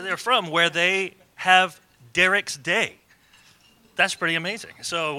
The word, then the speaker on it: Dirckx: It is D-I-R-C-K-X. Now, how do you say this? So all summer when we were interacting in my mind, I was saying Dirckx.